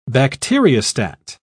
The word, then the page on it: bacteriostat: British English